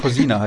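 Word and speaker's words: Kozina: Und da bin ich mir nicht sicher, wie es jetzt mit dem Kresimir Kocina, der so ausgesprochen? Kosina heißt er, ja, genau.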